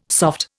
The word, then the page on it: soft: Indian